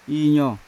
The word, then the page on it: injo: Yawa